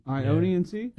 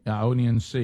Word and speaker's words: Ionian: I-O-N-I-A-N-C? – Ionian Sea? – Ionian Sea.